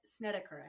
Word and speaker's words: Snedeker: Yes, Snedeker.